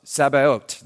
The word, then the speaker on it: Sabaot: By the way, that's the, Sabaot. Am I pronouncing it correctly? Sabaot. There we go.